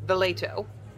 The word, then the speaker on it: Valeto: Did you say her name was Fileto? Valeto. – Valeto. – With a V.